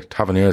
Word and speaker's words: Tavernier: I want to say Tavernier or Tavernier. Tavernier, I think, is the way they pronounce it.